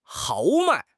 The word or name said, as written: 豪迈